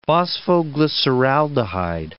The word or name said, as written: phosphoglyceraldehyde